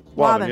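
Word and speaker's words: Waban: Marsha in Wabun. Is that how you say it, Newton? – Wabun. Yeah, okay.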